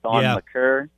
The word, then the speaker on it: Thon Maker: But what the Bucks need, in my opinion, this guy hasn't been said, but I love this guy, Thon Maker, aka Thon McCurr. That's how you say his name.